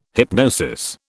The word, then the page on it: hypnosis: British English